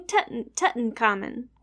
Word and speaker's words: Tutankhamun: Did I say that right? – Yeah. – Yeah, King Tutankhamun, more commonly known as your boy, King Tut.